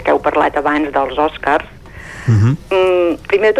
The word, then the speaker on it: Oscars: I la pronúncia l'hem de fer a mà oberta, és a dir, no hem de parlar dels Oscars, Oscars, sinó dels Oscars.